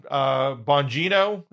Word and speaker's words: Bongino: I mean, Dan Crenshaw was fairly, pretty hardcore far right guy, getting dragged by Dan Bongino.